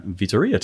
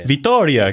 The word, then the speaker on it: Vittoria: and how somebody was looking for advice on Vittoria tires. So here we go. – Vittoria, excuse me, pronunciation.